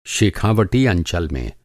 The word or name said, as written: शेखावटी